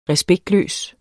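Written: RESPEKTLØST

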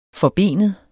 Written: forbenet

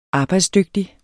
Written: arbejdsdygtig

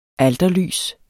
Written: alterlys

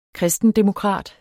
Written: kristendemokrat

